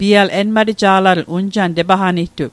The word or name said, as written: enmateja'latl